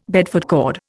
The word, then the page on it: Bedford cord: Indian